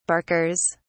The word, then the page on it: barkers: British English